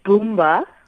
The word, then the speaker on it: Boomba: It's Boomba. – Yeah. – Dot Mobi. – Okay, Boombadotmobi. Okay. – Yes. – Tell us about the name first of all. – The name actually, Boomba is a slang name for a dung beetle in Siswati.